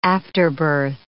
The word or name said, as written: afterbirth